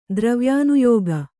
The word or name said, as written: ದ್ರವ್ಯಾನುಯೋಗ